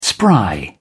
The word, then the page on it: spry: British English